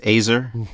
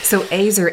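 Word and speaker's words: azer (עזר): Yes, azer. – Okay. Yeah. I always remember it because it rhymes with razor. So azer, and that word means what? – Like warrior.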